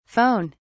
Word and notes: phon: British English

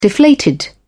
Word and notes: deflated: British English

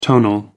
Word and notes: tonal: American English

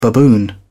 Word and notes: baboon: British English

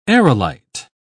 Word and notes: aerolite: British English

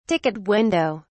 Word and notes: ticket window: British English

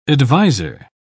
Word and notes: advisor: British English

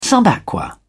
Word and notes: sub aqua: British English

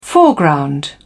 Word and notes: foreground: British English

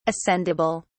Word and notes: ascendable: British English

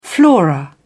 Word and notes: flora: British English

